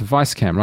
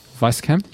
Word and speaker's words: Weisscam: Now, it's Weisscam, right? Because the W is pronounced as a V. – Yeah. – Weisscam, yes.